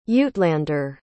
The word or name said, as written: uitlander